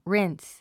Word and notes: rinse: ríns; リィンス

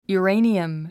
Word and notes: uranium: juəréiniəm; ユゥレイニアム

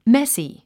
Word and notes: messy: mési; メッスィ

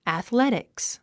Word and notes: athletics: æθlétiks; アスレティクス

